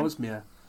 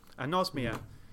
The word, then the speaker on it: anosmia: I was also interested because in all of that sort of the brain failing senses, because you suffer with, you have like amnosia, is it? How do you pronounce it again? – Anosmia. – Anosmia.